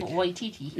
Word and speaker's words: Waititi: Waititi.